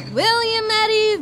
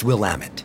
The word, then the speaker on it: Willamette: Now presenting, fresh from the fields of, the Willamette Valley. – It's pronounced Willamette. – Oh, yep, sorry.